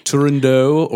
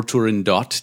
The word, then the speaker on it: Turandot: Turandot or Turandot, depending on which pronunciation you accept.